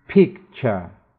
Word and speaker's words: picture: picture 图片照片 ture 四个字母一般发 ch